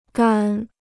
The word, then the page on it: 杆: gǎn